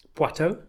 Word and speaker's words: Poitou: Poitou? – Poitou, in France. – Oh, Poitou. – Poitou.